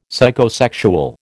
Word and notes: Psychosexual: American English

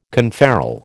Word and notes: conferral: American English

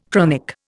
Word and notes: chronic: Indian

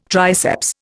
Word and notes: triceps: Indian

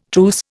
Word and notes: truce: Indian

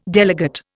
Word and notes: delegate: Indian

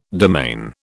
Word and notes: demesne: British English

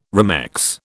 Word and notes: remex: British English

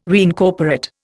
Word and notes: reincorporate: Indian

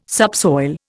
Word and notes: subsoil: Indian

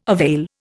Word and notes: avail: Indian

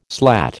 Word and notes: slat: American English

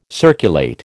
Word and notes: circulate: American English

